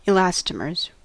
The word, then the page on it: elastomers: American English